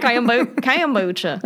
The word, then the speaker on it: kombucha: Kombucha. I'm saying it right. I know. – Kombucha. – That's it.